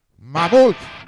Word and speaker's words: Mammoth: Mamut, que en inglés pues se dice también así, mamut. Se escribe de otra manera, pero suena igual.